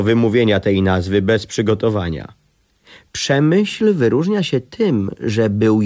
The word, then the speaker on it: Przemyśl: Słowo to w oryginale brzmi jak półtora kichnięcia, powiedzmy jak drive z półwolejem, coś w rodzaju Przemyśl.